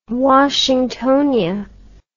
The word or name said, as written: washingtonia